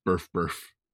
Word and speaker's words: Birth X Birth: Ichi, I think it's supposed to be pronounced Birth Birth.